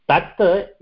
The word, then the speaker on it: तत्: Tata is pronounced as tata. It is not tata. The correct pronunciation should be tata. Because the last tata in the tata is half only. It is halantam. So it is called as half. We have to pronounce it for half the time what we pronounce it for the full tata. So it will become tat.